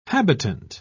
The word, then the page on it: habitant: British English